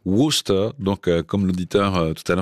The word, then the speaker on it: Worcester: Worcester Sauce.